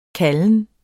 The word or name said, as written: KALDEN